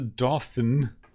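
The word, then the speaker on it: Dauphin: So this is the episode that my entire life I misread as the Dolphin. – Which is, like, you know, pretty close to how it's actually pronounced. So. – Yeah. So it's the Dolphin, apparently. – It's French again.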